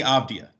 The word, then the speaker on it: Avdija: Denny Avdija. That's the right way to say it.